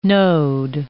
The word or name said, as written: node